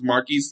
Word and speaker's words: Markese: Marquise spelled M-A-R-K-E-S-E. Again, my name is spelled M-A-R-K-E-S-E 95 Sports, and that's all together.